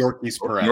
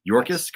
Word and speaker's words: Yorkis: Yorkis Perez. – Yorkis Perez. – Yorkis, correct? – Yorkis.